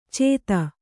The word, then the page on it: ಚೇತ: cēta